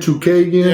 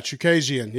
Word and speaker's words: Chookagian: All right, so let's talk a little bit about uh, Valentina and her fight against uh, Shikojian. Yeah. – Isn't that how you say her name? – Again, Katlyn Chookagian. – Yeah, Chookagian.